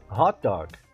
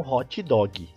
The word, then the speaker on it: hot dog: Então, pronúncia correta: hot dog. E não hot dog.